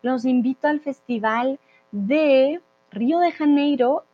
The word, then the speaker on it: Río de Janeiro: pero en español sería Janeiro, esa J para nosotros es, eh, viene de aquí, es fuerte, Río de Janeiro.